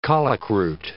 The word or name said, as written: colicroot